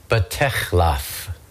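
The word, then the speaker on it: batechlaf (בתחלף): And where we see the English word changed or transformed, the original Aramaic word there is batechlaf.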